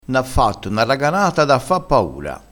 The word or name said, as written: raganata